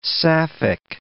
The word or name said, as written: sapphic